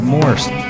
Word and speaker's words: Morse: And they were going to check out Steve Morse. I was like, who is this guy? There's no YouTube. There's no Wikipedia. I'm like, Morris? How do you spell that? Turns out, of course, it's like Morse code.